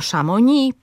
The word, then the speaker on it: Chamonix: Ten akcent odróżnia także język polski na przykład od języka francuskiego z akcentem na ostatniej sylabie. Słyszymy tam Chamonix, Paris, Montreux, Beaujolais i podobne.